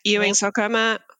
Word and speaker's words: Ewing sarcoma: But born and raised in South Africa, lost your leg to Ewing sarcoma. Is that how you say it? – Ewing sarcoma.